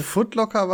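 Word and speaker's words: Foot Locker: Auch beim Footlocker, oder wie das heißt, wie ist die Aussprache? – Footlocker war schon richtig.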